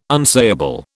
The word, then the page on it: unsayable: British English